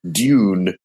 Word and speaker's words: Dune: I would say Dune. – Dune. Okay. Dune. – Yeah, it's like June, you see.